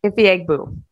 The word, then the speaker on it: Ifeagbu: Ifeagbu. – Yeah, it's literally pronounced how it's spelled. Like, you got it right.